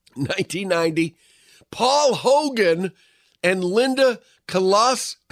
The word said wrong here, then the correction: Kozlowski: I was never able to pronounce her name right. It's Kozlowski, wed in Byron Bay, Eastern Australia.